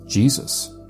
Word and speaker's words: Iesus: What they're leaving out is that the letter I was pronounced the way that we pronounce the letter J today. So if it's written I-E-S-U-S before 1524, it was still pronounced Jesus.